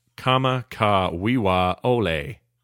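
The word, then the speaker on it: Kama ka wiwa ole (Kamakawiwoʻole): I'm here to tell you that number six is Somewhere Over the Rainbow by Israel Kamakakawalskawolili, which I'm told by Brian Ebbett is actually pronounced Kama ka wiwa ole.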